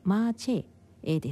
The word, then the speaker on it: 麻雀: なお中国語での発音はマーチェです。マーの箇所で上げ調子で発音して、チェのところで高くアクセントをつけてエで下げる、合ってるでしょうか。